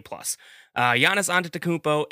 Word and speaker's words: Giannis Antetokounmpo: Giannis Antetokounmpo and the Milwaukee Bucks, the number one seed.